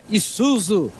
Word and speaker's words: Isuzu: Diego, se pronuncia Isuzu. Isuzu. Beleza? A marca do caminhão. Isuzu.